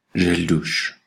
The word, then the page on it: gel douche: /ʒɛl.duʃ/; Bruxelles, Belgique